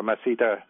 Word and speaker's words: masseter: Và trong cái những cái cơ ngậm miệng lại có hai cái cơ quan trọng là cái cơ masseter, tiếng Mỹ thì người ta đọc là masseter,